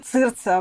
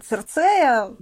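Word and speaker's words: Circe: Шрифт, который я, кстати, у тебя сейчас буду спрашивать, как правильно читается. Сирса, Серсея. В общем, я слышала много вариантов. Я говорю цирца обычно. Если совсем литературно цирцея, мы все говорим цирца.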